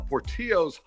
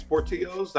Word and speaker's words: Portillo's: But yeah, so for, I think it's Portillo's. That's me pronouncing it the Spanish way, if nothing else.